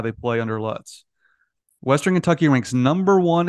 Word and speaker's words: Lutz: Will Lutz in the NFL is pronounced Lutz with the same spelling, so I think we go Lutz.